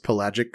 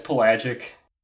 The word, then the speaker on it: pelagic: Oh, so can either of you tell me whether I pronounced pelagic correctly? – You did.